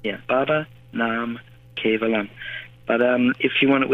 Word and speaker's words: Baba Nam Kevalam: So it's pronounced Baba Nam Kevalam.